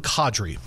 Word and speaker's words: Kadri: Nazem Kadri.